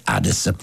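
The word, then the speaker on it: Adès: Lo so che l'accento è sulla E, però una volta intervistato fu lui stesso a dire: no, il mio cognome è Hades.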